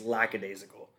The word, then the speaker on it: lackadaisical: Oh, sorry. I pronounced it wrong. It's lackadaisical. Lackadaisical. So I said laxy-daisy.